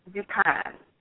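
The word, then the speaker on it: dukaan (दुकान): And store is dukaan in Hindi. D-U-K-A-N-A, dukaan. So one thing that you all can notice from all of these words, or most of them at least, is that when they end in an A, in English we are trained to pronounce the A. But in Hindi, you ignore the A.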